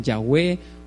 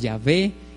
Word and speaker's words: Yahweh: que a muchos no las dicen que no se pronuncia, otros la pronuncian Yahweh, otros Yahvé.